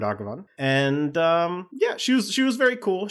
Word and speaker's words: Raghavan: He posted a great video with the pronunciation because it is not Ragavan. It is Raghavan, or Raghavan. When you're dealing with names from languages that are not English or not even rooted in Latin, I'm thinking specifically about like Hindi or various other Sanskrit derivative languages, or even like Japanese or something. The vowels are usually going to be pronounced the same in a name. So it's the A's in this are pronounced like with an emphasis on the first syllable.